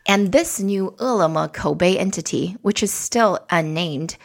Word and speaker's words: Koubei: Kobe, that's spelled K-O-U-B-E-I.